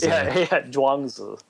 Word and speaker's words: Zhuangzi: Zhuangzi.